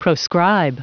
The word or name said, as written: proscribe